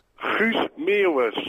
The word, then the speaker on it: Guus Meeuwis: Guus Meeuwis.